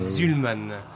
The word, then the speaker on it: Szulman: Alors, j'ai écorché votre nom de famille, hein, c'est Julien Schulman. – Schulman. – Schulman. Alors, S-Z-U-L-M-A-N.